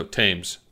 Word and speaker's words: Thames: Or should I say Thames? – No, Thames. – Okay. – The H is silent.